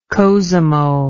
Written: cosimo

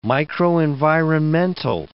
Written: microenvironmental

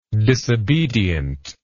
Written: disobediently

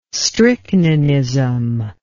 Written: strychninism

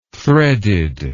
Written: threaded

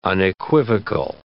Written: unequivocal

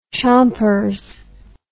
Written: chompers

